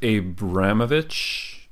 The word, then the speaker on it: Abramovich: Abramoff. Uh, what's his name uh, how do you say his last name I think it's Abramovich.